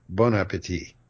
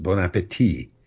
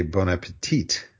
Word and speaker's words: bon appétit: Now, because the T at the end, that last T, has no E after it, so that last T is not pronounced bon appétit, but a lot of waiters don't know any better, and they say bon appétit.